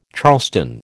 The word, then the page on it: charleston: American English